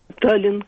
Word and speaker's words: Таллин: Безусловно, в конце слова произносится только одно «Н». «Таллин», конечно.